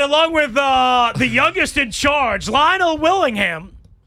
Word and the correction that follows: Linnell: You need to pronounce my name correctly. It's Linnell Willingham. – It's not Nell.